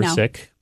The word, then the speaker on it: Sikh: But you practice, as you say, Sikhism, S-I-K-H. Most of us see that word and we think a Sikh, but are we mispronouncing it? – Yeah, I mean, there's two pronunciations. The original in the Punjabi language is Sikh. – Okay. – So we say Sikhi as the name of our tradition. And in English, that would come out as Sikhism. Sikh is the colonial pronunciation. So when the British came to India, they started saying Sikh.